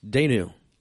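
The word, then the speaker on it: Dayenu: Danu, break that down. That was good stuff. Did I say that right? – You did.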